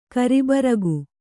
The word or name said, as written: ಕಾರುಬಾರಿಕೆ